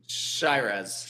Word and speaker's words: Chairez: Chairez. Did I pronounce his name wrong? Is that a typo? – Chairez.